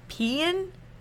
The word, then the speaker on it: paean: It's but this is spelled P A E A N. So I'm pronouncing the E A as like Caesar. So we're gonna go pean.